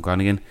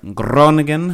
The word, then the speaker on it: Groningen: I know they played in Groningen in the Crowbar. All right, Groningen, I should say, sorry.